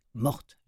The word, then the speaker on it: Mohr: Une des résidentes, un jour, Madame Mort, est retrouvée morte, la, la, bien nommée Madame Mort, mais ça s'écrit M-O-H-R.